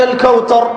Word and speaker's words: الكوثر: الثاء ينطقها تاء: إنا أعطيناك الكوثر. الكوثر ثاء مش تاء، وغيرها من الأخطاء.